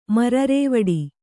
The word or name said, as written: ಮರರೇವಡಿ